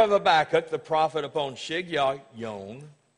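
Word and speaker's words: Shigionoth: Here's the way it's pronounced in the Hebrew. It's pronounced this way. Shig Yah Yom.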